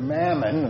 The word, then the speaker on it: mammon: Those who translate the Greek word mammon, and that's the way it's pronounced, mammon, when they translate it as money, they've unnecessarily narrowed its intent.